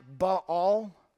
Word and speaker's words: Baal: Now, if Baal were real, and he's not, by the way, it's pronounced Baal. We say Baal, but it's Baal. There's a diphthong, two vowels in a single syllable. But I'm going to say Baal because that's how we pronounce it in the States.